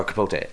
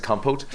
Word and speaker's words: Capote: Or Capote. I don't know why I just pronounce it Compote.